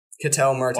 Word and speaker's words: Ketel Marte: I mean, they have to, except for Ketel Marte. Is that how you say his name? – Ketel Marte.